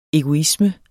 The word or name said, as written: egoisme